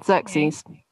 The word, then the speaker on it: Xerxes: The reigning, like, powerful kingdom at the time is this Persian kingdom, of which Xerxes, do we say it like that? – Yeah, Xerxes.